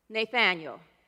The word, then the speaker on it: Nathanael: And this is when Jesus meets Nathanael. And in here is pronounced, it's pronounced Nathanael.